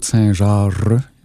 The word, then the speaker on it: Saint-Jarre: Mon nom est Claude Saint-Jarre, J-A-R-R-E. Ma mère disait prononce tes R, si tu veux que les gens comprennent ton nom.